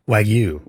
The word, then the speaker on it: Wagyu: It is Wagyu, or how do you say it? – Wagyu. – Wagyu. Or? – Why, so there's Wagyu or Wagyu. Okay. I'm a Wagyu guy.